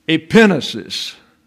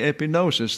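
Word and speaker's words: epignosis: Epinesis. I can't help the fact the accent's on the second syllable. Am I supposed to change? Look, I know everybody wants to say epinosis.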